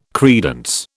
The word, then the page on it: credence: British English